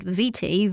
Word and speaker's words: Viti: The story goes that Captain Cook asked the Tongans what the name of the islands to their west was, and he heard Fiji when the Tongans actually said Viti, V I T I, so it became Fiji from the mishearing of a Tongan pronunciation.